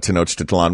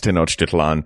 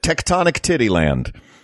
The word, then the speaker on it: Tenochtitlan: And he first looked at that word, not realizing how to immediately pronounce Tenochtitlan. He called it Tectonic Titty Land.